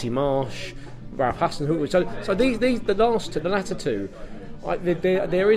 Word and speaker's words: Hasenhüttl: Hasenhüttl.